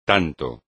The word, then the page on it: tanto: espagnol